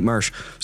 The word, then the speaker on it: Muersch: So we got our 20-something episode running right now, and we got Nate Muersch, right? Am I saying that right? – Yeah. – So we got Nate Muersch.